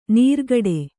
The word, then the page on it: ನೀರ್ಗಡೆ: nīrgaḍe